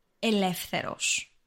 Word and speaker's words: ελεύθερος: So the word for free is ελεύθερος, and it's an adjective. – It's a long word. – Let's break it down into syllables. Ελεύθερος.